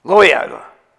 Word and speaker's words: Loyola: I always have trouble pronouncing it, so I was wondering if I was going to. Loyola. Oh, that's the way you guys pronounce it.